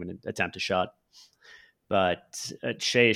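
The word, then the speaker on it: Shai: Yeah, I was most impressed by his defense on Shea. And thank you for pronouncing that right, because I constantly mispronounce it as Shy, if nobody corrects me.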